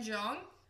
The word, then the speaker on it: Jeong: Ken Jeong.